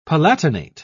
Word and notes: palatinate: British English

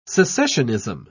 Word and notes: secessionism: British English

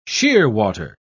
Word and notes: shearwater: British English

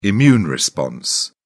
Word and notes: immune response: British English

